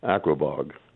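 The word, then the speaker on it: Aquebogue: I don't know how to say this town. Aquabog? – Aquabog. Yeah. – Aquabog.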